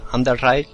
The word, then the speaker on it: Underride: El Unride, eh, Underride, mm, lo he dicho como, como se escribe, es una especie de Fallout Metro 2033 en 2D tiene una pinta acojonante.